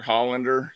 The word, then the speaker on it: Hollander: I would assume they mentioned we mentioned a couple episodes ago when they had on the shadow Justin Herlander, which is Hollander. Excuse me, yeah. I can't say his name.